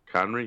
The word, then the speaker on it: Khonry: Cahorny? Not. Conry? – Conry? Conry? – I don't know. – Conry? – Conry, yeah. K-H-O-N-R-Y.